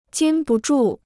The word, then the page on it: 禁不住: jīn bu zhù